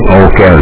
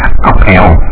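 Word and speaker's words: Óc Eo: Tiếng Okeo là chữ ngọc, nhưng mà sao những người Tây Phương họ âm thì chưa tin rồi đọc là ốc eo. Là ốc eo không nghĩa gì, còn cái Okeo là cái ngọc tiền, là cái chữ ngọc.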